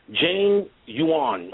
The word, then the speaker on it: Jianyuan: This name is, the name of the time is spelled J-I-A-N-Y-U-A-N. Jane Yuan, I guess, opinion also.